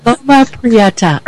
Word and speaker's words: Loma Prieta: Yeah, I do too, but Loma Prieta earthquake.